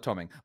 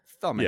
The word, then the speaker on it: thumbing: we always call it tumming. Well, of course, we say tumming. A lot of the Irish, we don't pronounce our THs, so thumbing.